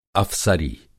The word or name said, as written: अफ़सरी